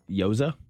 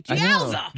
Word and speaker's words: gyoza: G-Y-O-Z-A. I'm going to assume the G is silent. Yoza? – Yowza, baby. No, I'm sorry. – That means you're wrong. – The G is not silent. Gyoza.